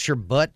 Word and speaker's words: sherbet: It says sherbet. Actually, it's pronounced sherbut. It's B-U-T in the pronunciation.